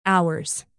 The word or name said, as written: ours